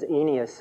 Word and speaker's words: Aeneas: And then in Acts, Luke tells of Peter's healing and restoration of Aeneas. Excuse me, the different way of pronunciation, Paul. That's the way it is. I learned it as Aeneas in the dictionary.